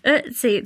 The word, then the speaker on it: Ötzi: Is it Ötzi? How do you pronounce it? – Oh, yeah. Ötzi.